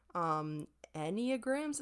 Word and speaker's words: Enneagram: So the things we're going to talk about today, we looked into love languages, Enneagrams, is that how it's pronounced? Enneagram? – Yeah.